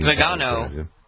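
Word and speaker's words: Viganò: Carlo Maria Vigagno. Do you know who Carlo Maria Vigagno? Vigagno. I'm believing. – Vigano. – Vigano, that's how you pronounce it? – Mm-hmm.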